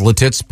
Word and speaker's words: Lititz: L-I-T-I-T-Z. – Yeah. It's Lititz. Been mispronounced. I don't know if it was mispronounced on purpose. – It's Lititz, Pennsylvania, isn't it? – I didn't hear it pronounced like that.